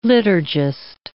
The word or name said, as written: liturgist